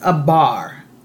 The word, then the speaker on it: abar: So in the Strong's Concordance, or in the Greek and Hebrew, in Strong's number 5674, it's pronounced abar, right? Abar, A-B-A-R.